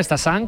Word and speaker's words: Stassin: A pronunciarlo bien, Stassin, como nos han apuntado, y Félix Correia, como dice José, con muy buena pronunciación portuguesa.